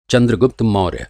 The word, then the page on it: चन्द्रगुप्त: Chandragupta